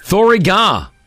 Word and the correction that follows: bore da: No, bora. – Oh, bora. – Da.